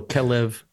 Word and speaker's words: Kelev: And then it's to be distinguished from the word Kelev. Kelev is the word for dog. – I got stories. – You've got, okay. So Kelev is the word for dog. They're spelled the same way, Kaf Lamed Bet, but they're vocalized very differently.